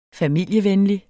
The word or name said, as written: familievenlig